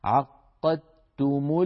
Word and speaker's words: عقدتم: لذلك علماؤنا لو نظرتم في المصحف لم يجعلوا على الدال شيء، يعني الدال ما عليها سكون وهي مجردة من الحركة، والتاء التي بعدها عليها شدة وضمة، شدة الشدة معنى هذا الحرف مشدد، لأن النطق يكون بتاء مشددة عقدتم الأيمان.